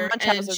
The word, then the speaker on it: Munchausen: Is it Manchauser or Manchauser? How do you say it? – Oh, interesting. – I think it's Munchausen syndrome. She's a survivor. – No, Munchausen.